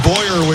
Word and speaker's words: Boyer: Looks like Bucher, and it's pronounced Boyer, we are told, for the Rangers.